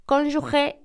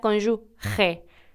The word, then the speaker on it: cónyuge: Y se escribe, al final, la última sílaba, G-E, y se pronuncia cónyuge. Pero muchísima gente pronuncia cónyuge, como si se escribiera G-U-E en lugar de GE.